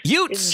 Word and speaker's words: Jutz: How do you pronounce Tom's last name? Is it Jutz? Jutz? – Jutz. – German. Jutz.